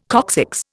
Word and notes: coccyx: Indian

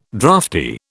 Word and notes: drafty: British English

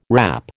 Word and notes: wrap: American English

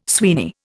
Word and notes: Sweeney: Indian

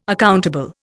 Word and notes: accountable: Indian

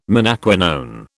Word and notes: menaquinone: British English

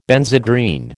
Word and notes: Benzedrine: American English

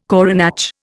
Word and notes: coronach: Indian

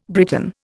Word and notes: Briton: Indian

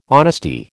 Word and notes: honesty: American English

